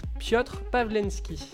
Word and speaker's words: Piotr Pavlensky: Alors ça va être sur mm, mm, Piotr Pavlensky.